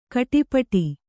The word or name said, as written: ಖಟಿಪಿಟಿ